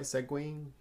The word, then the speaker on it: segueing: So, segueing into our next subject. Did I say that right? Segueing? Segueing? – You said it perfectly.